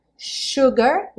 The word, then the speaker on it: sugar: Nessa frase, lembrando, sugar, o S aí vai ter um som de X, e nós temos três palavras terminadas em E, e esse E afinal não vai ser pronunciado.